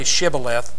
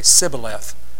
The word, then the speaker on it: Shibboleth: Because certain band of the Jews couldn't say, they said they couldn't say Shibboleth. They couldn't pronounce the letter H in their words. They'd say Sibboleth.